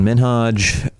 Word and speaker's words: Minhaj: Me, Hassan Minhaj. – Minhaj is how you say it. Mm-hmm. You've been saying it wrong.